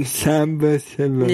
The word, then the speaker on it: Sambasivam: Sambasivam.